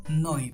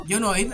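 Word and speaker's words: Yo Noid: Yonoid. En realidad, yo, Yonoid, así le decíamos nosotros porque así se escribe, Yonoid, pero en realidad es, es, Yo, Noid.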